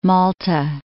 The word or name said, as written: Malta